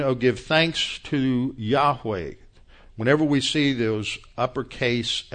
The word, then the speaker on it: Yahweh: Y-H-W-H, usually pronounced Yahweh, mistranslated many centuries ago as Jehovah. The way it's written in Hebrew, there are no vowels. So all you have is a Y-H-W-H. The Y is often transliterated into English as a J, so that's where you get the J. The W is usually translated as a V. In fact, in Hebrew, the W is often pronounced as a V, and so that's where you get the J and the V, the J-H-V-H.